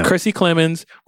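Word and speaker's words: Kiersey Clemons: So, Kersey Clemons, did I say it right? – That's right. Yeah. – Kersey Clemons, we know her from Dope, which, no.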